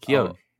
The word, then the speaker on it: Keoghan: Wait, that's how you say because, his last name? – Yeah, that's how you say it. Keoghan. – I always say Keoghan. He's Irish. – I've heard it pronounced Keoghan.